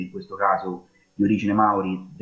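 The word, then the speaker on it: Māori: ma è Maori perché c'è il Macron, ovvero quella lineatina orizzontale sopra là, e quindi la pronuncia corretta di questo popolo tradotto in italiano è appunto Maori.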